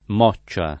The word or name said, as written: Moccia